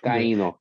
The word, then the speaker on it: Taino: The Taino, I think, is the Taino. I can't remember the pronunciation of Taino.